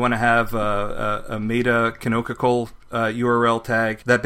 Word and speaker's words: canonical: Yes, you pronounce it canonical. Rog is just correcting me in VIM. Yes, it's canonical is the correct way to pronounce it.